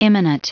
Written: immanent

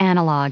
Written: analog

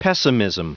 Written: pessimism

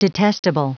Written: detestable

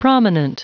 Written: prominent

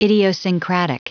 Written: idiosyncratic

